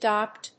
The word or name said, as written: DPWM